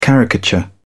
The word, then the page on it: caricature: British English